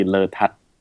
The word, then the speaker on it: Lertad: From Thailand, the very Thai way to pronounce it would be Letad, so the emphasis on the tat.